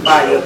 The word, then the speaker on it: Fiya: I am Fire, but the name is spelled F-I-Y-A.